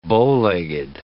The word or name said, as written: bowlegged